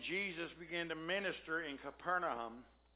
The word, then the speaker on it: Capernaum: When he left the wilderness, he went to Capernaum, is how it's pronounced.